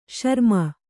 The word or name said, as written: ಶ್ರಮೆ